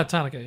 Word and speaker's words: Tanaka: Is that how you say it? Or Tanaka? – No, Tanaka. Yeah.